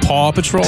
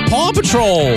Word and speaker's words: Paw Patrol: 4 tickets to Paw Patrol. Live. – You mean Paw Patrol? – Did I not say it correctly? – You said Paw, like Grandpa.